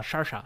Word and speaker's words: Saoirse: Aprendí a pronunciar Saoirse gracias al programa de Conan O'Brien de Estados Unidos, porque es a oírse. – Sí. – Y le preguntaban, ¿cómo se pronuncia? Saoirse, sí.